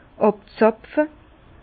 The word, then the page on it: àbzapfe: Bas-Rhin; Herrlisheim